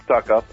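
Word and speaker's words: Succop: You do pronounce it stuck up. I know it's kind of strange, but you do.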